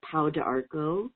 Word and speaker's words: Pau d'Arco: It's and pronounced maybe a little bit different in the U.S., but Pau d'Arco. We're literal with the pronunciation. It's P-A-U-D as in dog, apostrophe, A-R-C-O.